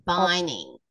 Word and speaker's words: Baining: BAINING, Bining. – Bining. Okay. – Yeah, Baining, Bining. – Bining. I pronounce it Bining.